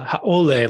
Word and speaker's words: haole: And like the more like white, you could say colonists, non-locals, or the haole, like you as you pronounce it, meaning people who are not of the land.